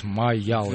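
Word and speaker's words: Mayall: А про кого мы сегодня говорим, это этот Джон Майлз, он, наверное, произносит, это, наверное, я его так просто так произношу, у него «С» на конце, вот. А тут «Майял».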